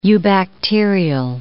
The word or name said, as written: eubacterial